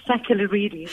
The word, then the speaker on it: Sakellarides: You actually said it perfectly. You said it like a Greek woman. It's Sakellarides.